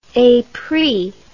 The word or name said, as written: epris